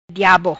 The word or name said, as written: Diabo